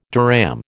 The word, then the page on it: dirham: American English